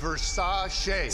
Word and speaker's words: Versace: Pronounced Versace. Singer? That's Liberace.